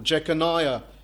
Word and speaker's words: Jeconiah: He's speaking about a king, King Jeconiah, or Jeconiah, as some pronounce it.